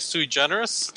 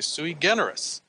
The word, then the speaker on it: sui generis: I think a lot of people say sui generis. Depending which form of Latin you're used to, you might say sui generis.